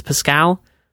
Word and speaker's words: Pascale: So it's Pascal. – Just yeah, Pascal is kind of the American version, I guess.